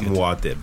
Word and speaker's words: Muad'Dib: It's more like Muad'Dib.